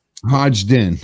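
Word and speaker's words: Hodgdon: Hodgden. Okay. – Don't pronounce the first D. The first D silent, basically. – Yeah. It's not Hodgden. – Okay. Yeah. It's not Hodgden. Yeah. It's Hodgden.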